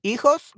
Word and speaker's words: hijos: Esther, because hijos, remember that the H is silent, so it sounds like EE.